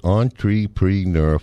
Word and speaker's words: entrepreneur: But first of all, you pronounce it entrepreneur, four syllables, E-N-T-R-E, P-R-E-N-E-U-R. It's a French word.